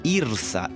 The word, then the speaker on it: IRSA: Pues bien, comencemos con una definición para entender qué es lo que es IRSA.